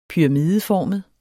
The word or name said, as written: pyramideformet